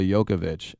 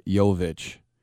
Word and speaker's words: Jovic: But whenever I see his name, I want to say Nikola Jokovic. It's just Nikola Jovic. It's without that extra syllable in there.